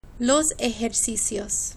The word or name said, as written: los ejercicios